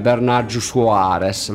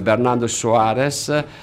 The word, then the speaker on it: Bernardo Soares: In quel caso si presentava come Bernardo Soares, lo pronuncio come i telecronisti brasiliani, quando faceva gol qualcuno, gol di Bernardo Soares, no?